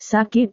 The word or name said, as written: sakit